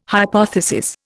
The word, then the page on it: hypothesis: Indian